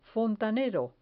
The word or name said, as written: fontanero